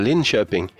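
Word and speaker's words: Linköping: How was the pronunciation of Linkoping? – I think Niles had that really nailed there, Linkoping. – Linkoping, okay. – Yeah, Linkoping. That was really good.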